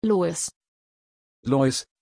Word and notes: loís: German

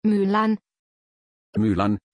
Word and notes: Mylann: German

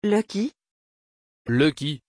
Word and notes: Lucky: French

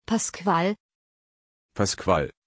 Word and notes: pascual: German

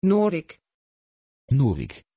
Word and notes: Norick: Dutch

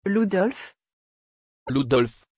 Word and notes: Ludolf: French

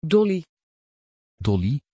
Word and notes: Dolly: Dutch

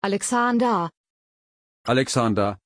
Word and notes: Alexandar: German

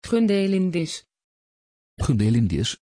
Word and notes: gundelindis: Dutch

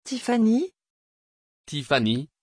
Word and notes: tiphanie: French

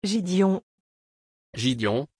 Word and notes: gidion: French

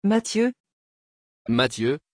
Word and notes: Matthieu: French

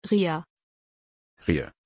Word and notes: Rhea: German

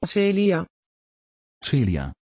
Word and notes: celia: Dutch

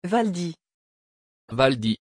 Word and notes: valdis: French

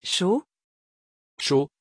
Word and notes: cho: French

